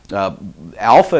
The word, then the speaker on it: alpha: So I would say alpha is pronounced as the A in father. Okay, I say father, so I say ah for alpha, right?